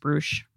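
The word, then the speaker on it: Bruush: And I don't know if you remember, but I actually do think, it's pronounced Brush. – Oh, okay. – Yeah. – Good to know. Well, I mispronounced it, guys.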